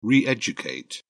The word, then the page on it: re educate: British English